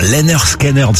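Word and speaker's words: Lynyrd Skynyrd: Alors Lennart Skynyrd, ça se dit comme ça, oui.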